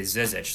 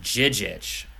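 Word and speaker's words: Žižić: He did a detailed breakdown of how to pronounce what I would thought was Ante Zizic.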